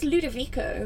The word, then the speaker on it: Ludovico: Like my favourite instrumental composer who's not a soundtrack composer is, I can never pronounce his name, but it's like Ludovici Einaudi. – I think it's Ludovico. – That's it. – Ludovico.